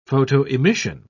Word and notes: photoemission: British English

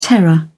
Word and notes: terror: British English

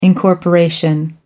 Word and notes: incorporation: British English

